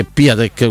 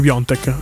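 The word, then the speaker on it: Piątek: Sto vedendo, no, non è Piontek, è Piatek, quell'altro. – No, quello, Piontek, si legge Piontek, è scritto Piatek, però.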